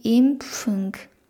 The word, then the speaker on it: Impfung: 例えば、ワクチン、皆さんドイツ語で何て言うかご存知でしょうかワクチンはドイツ語で、 d i m p f u n g d Impfung っていうふうに言います。